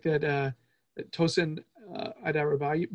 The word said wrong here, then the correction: Adarabioyo: Adarabioyo. I think that's how to say it.